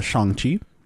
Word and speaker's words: Shang-Chi: Look, dude, I just watched Shang-Chi. And I believe that's how it's pronounced in the movie. So I'm just going to go with that pronunciation.